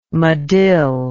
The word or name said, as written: medill